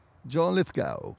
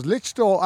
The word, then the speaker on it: Lithgow: ik heb het altijd als Litchtow uitgesproken. – Litchtow, ja, ja kan ook. Misschien zit ik er wel naast.